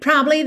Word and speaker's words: probably: Aqui é muito curioso que essa palavra provavelmente, probably, ela tem três sílabas, mas frequentemente aparece com duas. Probably vira probably, probably. É como se esse A do meio fosse tão fraquinho que de um B para o outro eles se juntassem.